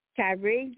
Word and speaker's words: Kyrie: Okay, well, we were speaking about Kyrie.